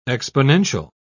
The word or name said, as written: exponential